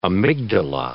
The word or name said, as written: amygdala